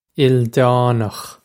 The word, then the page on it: ildánach: il-dawn-okh; Irish